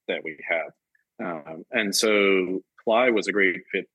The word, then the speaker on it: Plai: Is it pronounced Ply? – Ply, actually. I think it has some meaning in Ukrainian, I believe. – Spelled P-L-A-I. Now, they call themselves a people and performance management tool.